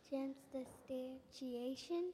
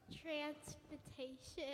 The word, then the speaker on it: transubstantiation: Transubstantiation.